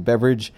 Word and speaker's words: Beveridge: I am on most of them, and my full name is what I use, and Beveridge is spelled B-E-V-E-R-I-D-G-E, not like the drink. That is Beverage. I have no idea why people pronounce that Beverage.